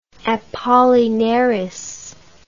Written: apollinaris

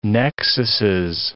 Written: nexuses

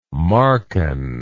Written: Marcan

